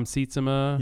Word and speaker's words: Sietsema: I have two male critics I supervise, and then Tom Sietsema.